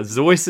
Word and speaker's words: Seuss: Now, Zeus is interesting. Because that is how... – That was how it was originally pronounced. – That's his German name. – It rhymes with a voice.